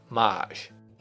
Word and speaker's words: mage: If it appears to have a very surprised look on its face, that's a mage. – The word is pronounced Maj.